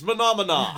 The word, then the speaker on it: monomania: It's pronounced monomana.